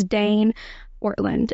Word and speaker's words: Ortlund: I think it's Dane Ortlund is how you pronounce his name.